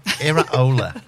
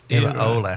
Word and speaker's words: Iraola: And this is how you pronounce it. – Mm. Iraola. – What? – Iraola. It's a second name.